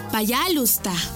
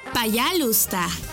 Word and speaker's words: Пожалуйста: Y se dice Пожалуйста. ¿Cómo? Пожалуйста. Una vez más. Пожалуйста. Y se escribe Пожалуйста. P A Y A L U S T A.